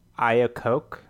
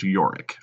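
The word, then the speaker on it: Iorek: I think the bear's name is like Iacoke or something. Iacoke? – You know what? That's close. It's spelled like it's I-O-R-E-K, and it's pronounced Yorick, apparently.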